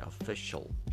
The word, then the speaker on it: official: یک ایده ای بدم ولی در زبان انگلیسی به این شکل تلفظ میشه official شل